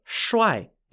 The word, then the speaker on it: Shuai: This piece is called the Shuai if you're on the red side, or Jiang if you're on the black side.